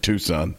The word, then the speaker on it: Tucson: You pronounce it Tucson.